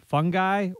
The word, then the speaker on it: fungi: It's the word for more than one fungus, F-U-N-G-I. I always got to think, do I pronounce it fungi or fungi? As I often do, I looked it up in an online dictionary and found that the correct answer to the question of fungi or fungi is yes. Turns out they're both acceptable pronunciations.